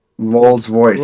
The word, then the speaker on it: Rolls Royce: Sorry, the 02 Royal Royce. – Rolls Royce. The vehicle. Royce. The car. – My bad. I always pronounce that fucking shit wrong.